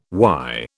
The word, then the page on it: WY: British English